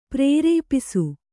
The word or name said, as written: ಪ್ರೇರೇಪಿಸು